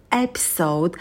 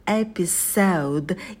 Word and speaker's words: episode: Эпизод, серия, это an episode, episode, ударение на первый слог, и у нас в конце будет дифтонг L, episode, и звук D будет звонким, не оглушайте его.